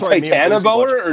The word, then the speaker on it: Titanoboa: And that's the only reason I watched Titan Boa. That movie looked fucking sick with the cover. – You mean Titanoboa or Titan Boa? – No, the first one. I said it wrong.